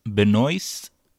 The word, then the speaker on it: Benoist: Benoit or Benoist. – She at least pronounces it like as Benoist without the T.